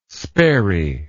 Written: sperry

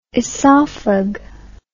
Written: esophag

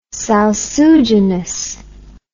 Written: salsuginous

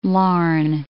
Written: Larne

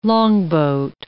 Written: longboat

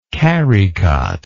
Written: carrycot

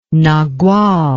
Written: nagual

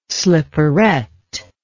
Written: slipperette

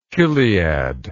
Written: chiliadic

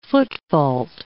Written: footfault

